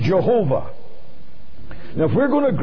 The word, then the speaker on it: Jehovah: And then he said, if I were reading that as a word, I would read it Jehovah.